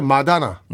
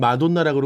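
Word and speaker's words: Madonna: 우리나라 원조 댄스의 뭐 최고의 그 댄싱퀸이고 한국의 마다나. – 마다나. – 아, 여러분 그 발음이요. 한국은 마돈나라 그러고. 네. 제가 미국을 다녀오니까.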